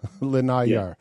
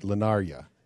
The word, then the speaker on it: Linaria: I just pronounce it differently than you do. Linayar. Yeah, I've stayed there. That's actually that seems to be the main port. Linaria is where I go.